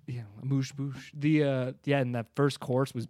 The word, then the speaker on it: amuse-bouche: But yeah, let's go to the first course, which I thought this term, amuse-bouche, I thought. – Say it again. – Amuse bouche. Isn't that how you say it? – Dude, I don't know. – Yeah, you got it.